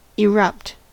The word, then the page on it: erupt: American English